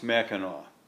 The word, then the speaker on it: Mackinac: The answer is, no matter how it's spelled, it's always pronounced Mackinac.